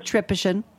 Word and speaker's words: Tripician: Did I say it right finally? Tripician? – Tripician.